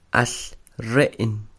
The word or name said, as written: al Re'n